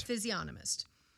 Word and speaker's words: physiognomist: Physiognomist.